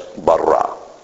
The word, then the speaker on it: bara: and call it B-A-R-A, Bara. So the word Bara, actually, we get our word created. So that it says, in the beginning, that's Bereshit, God, that's Elohim, created, that's Bara.